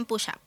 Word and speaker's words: push-up: Háblame de esa seña de identidad tan característica de Moda Samaya, los vaqueros push-up. ¿Se dice así? – Sí, se dicen push-up.